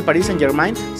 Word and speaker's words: Paris Saint-Germain: Aquí en México pues más fácil el PSG o el Paris Saint Germain, pero eh, creo que la forma correcta o, lo más parecido, porque tampoco mi francés es fluido, es el Paris Saint-Germain.